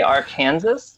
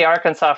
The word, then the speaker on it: Arkansas: And I know you may be wondering, why did I just say Arkansas? That's because Kansas pronounces that name for the river while it's in the state of Kansas. We still say Arkansas for the state, but we say Arkansas for the river.